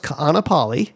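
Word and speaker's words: Kaanapali (Kāʻanapali): And probably those of you who are now thinking of Maui, you've seen that long word with a lot of K's and an A's and an I's, right? It's pronounced Kaanapali, and it's on the west side.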